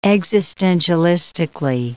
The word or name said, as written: existentialistically